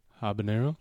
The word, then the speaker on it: habanero: Prison officials in North Carolina are calling for a criminal investigation after inmates allegedly correctional officers forced them to rub habanero. Did I say that right? – Habanero? – Yeah.